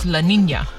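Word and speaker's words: La Niña: That's how it's spelled as Nina, but it's Spanish, so it's La Nina.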